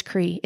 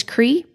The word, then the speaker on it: Kri: Now I just introduce myself as Kri. It's easier to remember. It's one syllable.